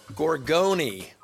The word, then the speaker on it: Gorgone: And it's actually pronounced Gorgone. You pronounce the E at the end. – Gorgone.